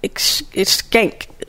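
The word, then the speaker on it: Schenck: It's Skank. It's hard to say because it's not Skank, but it's S-C-H-E-N-C-K.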